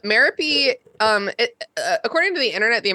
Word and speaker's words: Merope: When I Googled it, it was like, which I have to do a lot for this show, the UK pronunciation said Merope rhymes with therapy, which is something that she could have benefited from.